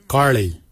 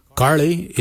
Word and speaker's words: giilyi: Darb is spelled D-H-A-A-B. It is a traditional knife, a darb. For boomerang, we say garli. Garli is the traditional name for boomerang. Garli is spelled G-I-I-L-Y-I, garli. For the traditional stone axe, we say gotch, gotch. Gotch is spelled G-O-O-D-J, gotch.